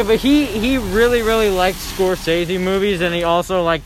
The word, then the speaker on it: Scorsese: Scorsese. – Fuck you. – It's pronounced Scorsese.